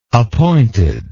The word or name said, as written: appointed